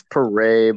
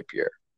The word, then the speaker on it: Pere: It's Pere, but no one gets it right, so it doesn't bother me at all. Most people say Pere.